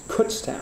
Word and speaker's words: Kutztown: Is that how you say it? – We pronounce it Kutztown.